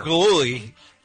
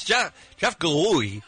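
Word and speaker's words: Gillooly: Yeah, he could never say that correctly. – Jeff Galooly It's Jeff Galooey, he would say every night on the news.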